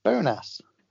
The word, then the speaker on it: Bonas: Well, you're saying the word bonus with a U, surely. – What, you want to say Bonas? – It's with an A. Bonas.